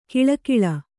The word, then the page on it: ಘಳಘಳ: kannada